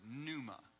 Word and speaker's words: pneuma: The P is silent in the Greek word, pneuma.